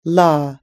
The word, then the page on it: law: American English